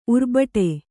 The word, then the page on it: ಉರ್ಬಟೆ: urbaṭe